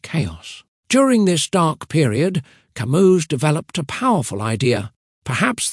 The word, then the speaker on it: Camus: Albert Camus, pronounced Albert Camus, was a philosopher born in Algeria in 1913.